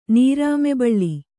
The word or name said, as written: ನೀರಾಮೆಬಳ್ಳಿ